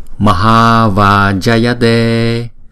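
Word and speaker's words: Maha Vajayate: và câu tiếng Phạn chúng ta đọc là Maha Va Jayate, Maha Va Jayate. Chữ này chúng ta đọc là Maha, Maha. Chữ Ha là đọc 2 nhịp, chữ Ma là đọc 1 nhịp, Maha.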